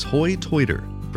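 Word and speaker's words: hoi toider: These accents are sometimes referred to as hoi-toiter, from the way they pronounce high tide, hoi-toit.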